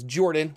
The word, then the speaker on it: Jorphdan: My name is Jordan, with a silent PH in the middle,